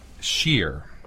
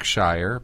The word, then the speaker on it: shiyr: Sheer. – Okay. – People see it and they think Shire because of the S-H-I-Y-R.